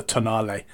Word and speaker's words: Tonale: They have a car called the Tonale, but it's spelt toenail.